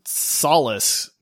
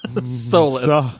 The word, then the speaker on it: Solus: You have to at least say his name right if you draft him. Solus. – No, wait, man. Saul. Like, it's Solus. – Saul, S-O-L.